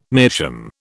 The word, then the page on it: meerschaum: British English